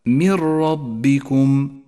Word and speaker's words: من ربكم: النون الساكنة بعدها حرف الراء، تسقط النون من اللفظ ولذلك هي رمادية اللون، وتشدد الراء التي بعدها، تلفظ هكذا: من ربكم.